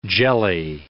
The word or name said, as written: jelly